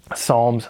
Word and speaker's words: Psalms: So the songs, literally just songs, Psalms, songs, silent P,